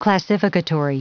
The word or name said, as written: classificatory